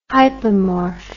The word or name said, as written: hypomorph